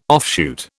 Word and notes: offshoot: British English

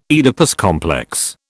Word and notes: Oedipus complex: British English

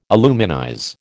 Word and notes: aluminize: American English

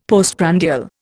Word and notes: postprandial: Indian

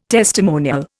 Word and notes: testimonial: Indian

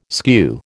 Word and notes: skew: American English

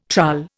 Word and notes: trull: Indian

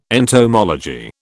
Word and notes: entomology: British English

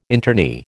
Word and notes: internee: American English

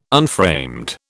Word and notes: unframed: British English

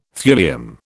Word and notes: thulium: British English

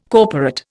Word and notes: corporate: Indian

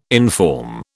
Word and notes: inform: British English

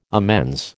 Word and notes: amends: American English

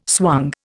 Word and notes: swung: Indian

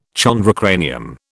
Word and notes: chondrocranium: British English

